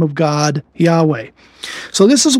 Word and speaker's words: Yahweh: Yahweh, which used to be called in some of the older versions Jehovah, but the proper translation or proper pronunciation or much closer pronunciation from the Hebrew is Yahweh,